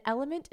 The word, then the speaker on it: LMNT: And Element is legit the queen of electrolyte replenishment. It's spelled like L-M-N-T.